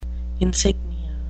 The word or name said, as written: insignia